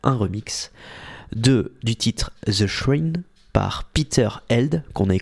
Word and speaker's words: The Shrine: S-H-R-I-N-E. The Shrine.